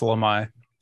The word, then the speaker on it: Lemhi: Lemhi, I might be saying that wrong. I'm really bad at pronunciation. – It's Lemhi.